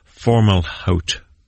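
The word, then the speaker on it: Fomalhaut: There's a star called Formalhaut. That'd be the right way to pronounce that one. Lots of different ways.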